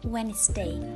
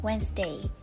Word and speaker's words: Wednesday: Thực chất từ này chỉ có hai âm tiết chứ không phải ba âm tiết như chúng ta lầm tưởng và ta phải đọc là Wednesday, Wednesday.